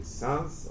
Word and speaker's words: Sens: I think the French pronounce it maybe Sens, or S-E-N-S. That's how it's written in French.